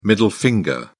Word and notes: middle finger: British English

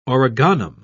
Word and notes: origanum: British English

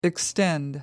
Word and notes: extend: American English

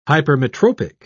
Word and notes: hypermetropic: British English

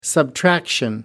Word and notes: subtraction: American English

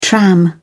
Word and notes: tram: British English

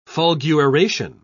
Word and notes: fulguration: British English

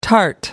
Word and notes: tart: American English

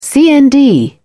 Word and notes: cnd: British English

